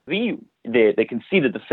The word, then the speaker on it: Ra: One of Stuart's favorite stories about the original movie is that so the god Ra is a very anglicized pronunciation of what they originally would have called the god. In Coptic, I believe it's pronounced Rea, so R-E-E-U-H, if you want to like think about it phonetically, like Rea. But in ancient Egyptian, that becomes an oo sound, so it's Riu.